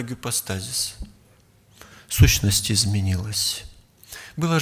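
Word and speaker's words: гипостазис (ὑπόστασις): Знаете, ипостась, точнее, греки произносили примерно так, гипостазис, использовали в таких случаях.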